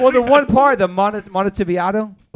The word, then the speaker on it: Montevideo: That you butchered, bro. – Okay, how do I say it? – Montevideo. – Montevideo. – Montevideo, yeah. Like a video, you know. – Oh, okay. – Video of Monty.